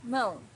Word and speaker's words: mão: Mão, hands. Mão's a little bit difficult to pronounce for beginners because of this nasal sound, um.